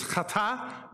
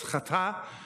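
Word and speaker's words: chata (חטא): The Hebrew is chata. That's how you say that. It's the furball, the chet that's there at the beginning of that. So it's chata. And that's how you say it.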